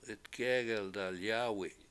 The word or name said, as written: 7itgEgel dalyAAwi7